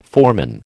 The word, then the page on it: foreman: American English